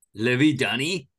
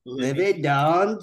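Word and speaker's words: Livy Dunne: How do you actually say her name? – Livy Dunn. – Okay. – Livy Dunn just rizzed up baby Gronk, and you won't believe what happens next.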